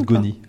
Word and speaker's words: Ngoni: Ngoni, comment on le prononce, le N? – Ngoni.